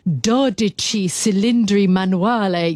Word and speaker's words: Dodici Cilindri Manuale: This is the Dodici Cilindri Manuale. Yes, it's a car, and I'm, you know, giving you the whole Italian pronunciation.